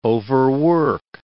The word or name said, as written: overwork